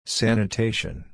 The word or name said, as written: sanitation